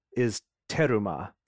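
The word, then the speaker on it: terumah: The a heave offering is teruma, is how it's said in Hebrew.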